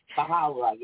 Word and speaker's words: Baha'u'llah: Is that how you say it? – Baha'u'llah, yes.